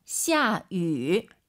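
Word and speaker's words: 下雨: 下위. 위 아니죠? – 샤유. – 입술을 동그랗게 말아서 위 하려고 하세요. 샤유. 샤유. 네, 이렇게 발음하셔야 됩니다. 절대 위, 입이 가로로 찢어지면 안 됩니다, 여러분.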